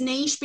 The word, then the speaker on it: niche: So uh, yeah, and research is important to have an industry, an angle, or a niche, niche for your American listeners. But it's niche because it's a French word and that's how it's pronounced.